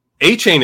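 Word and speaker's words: Achane: A-Chain is right though. And that's a fun way to pronounce that name. A-C-H-A-N-E. A-Chain. It's pretty sweet.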